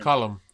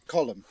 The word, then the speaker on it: Colm: One of them's called Colm, if that's how you pronounce it. – Colm. – Colm, thank you.